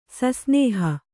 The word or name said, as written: ಸಸ್ನೇಹ